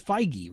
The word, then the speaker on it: Feige: IO9 reports that Kevin, and I always get, like, people pronounce his name, it's Feige, right?